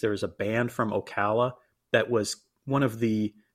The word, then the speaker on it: Ocala: I had to look up the pronunciation to make sure that I didn't call it Ocala. Ocala is the proper pronunciation.